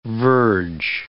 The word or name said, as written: verge